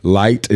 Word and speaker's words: Lite: So, like, light is spelled LITE space POLE, but the IOL is silent.